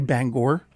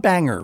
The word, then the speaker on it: Bangor: Joel Mann, how do you say Bangor? – Bangor. – Bangor, not banger, right? – No, Bangor. – Do you know anyone who says banger, Maine? – Yeah, a lot of people do.